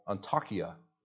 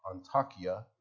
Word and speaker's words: Antakya: And today, it's called Antakya. That is the Arabic pronunciation, similar to Antioch, Antakya, but it is what it's called today.